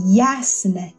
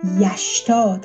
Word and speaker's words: یسن: ریشش یک لغتی هست که یسن یا یشتا تلفظ میشه